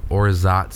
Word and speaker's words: orgeat: It's called Orzat syrup, but it's spelled O-R-G-E-A-T.